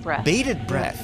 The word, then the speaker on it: bated breath: And so our many new listeners have been waiting with bathed bread. How do you say that? – Bated breath.